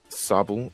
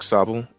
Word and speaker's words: Sobble: Because I like Sobble. I don't know how to pronounce it yet. – Sobble. It looks like Sobble.